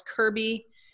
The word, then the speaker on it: Kerby: Alice Kerby is my Facebook name, and my last name is spelled K-E-R-B-Y.